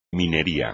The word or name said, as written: minería